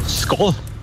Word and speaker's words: skål: Skål. – Wow, richtig? – Echt? – Ja, war sogar richtig betont. – Oh. – Wird Skal geschrieben, Skål, aber ausgesprochen.